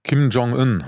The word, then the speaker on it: Kim Jong-un: Kim Jong-un heißt er richtig.